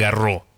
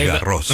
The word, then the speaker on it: Garros: Como ahora que se está disputando el torneo de Roland Garros, usted ya nos había explicado que no es Roland Garros. – No, es Garros. – Roland Garros, sí. Garros, claro.